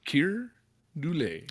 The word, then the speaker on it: Keir Dullea: Keir Dullea. I was wondering how to pronounce that. – Keir Dullea.